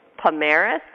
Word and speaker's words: Pomares: So Kim, I hope I say his name right, Pomeris. Did I say it right, Kim? – Yeah, thanks. – Okay. Kim Pomeris will be joining us right now,